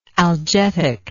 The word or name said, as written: algetic